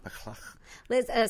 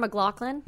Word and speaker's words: McLachlan: Was it McLachlan? Or I just let it go. McLachlan. Sarah McLachlan. – McLachlan. – Yeah. – McLachlan.